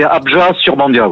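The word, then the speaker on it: Abjat-sur-Bandiat: Alors, oui, on ne prononce pas forcément le T final, c'est Abjad-sur-Bandia, oui.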